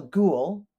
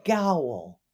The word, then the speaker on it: ghoul: A ghoul, spelled G-H-O-U-L, looks like it should be pronounced gowl, but it's not. It's an ooh sound, kind of like ooh.